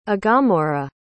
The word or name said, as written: aghamora